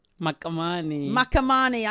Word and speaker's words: Makamane: Yes, this is the Eclectic Goddess here with the Speak Your Truth show and my very special guest, Sister Ret'sepile Makamane. – Makamane. – I keep saying it wrong.